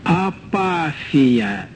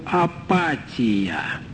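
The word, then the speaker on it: Апафия (ἀπάθεια): Апафия, или, как считается на Западе, по Эразму, апатия.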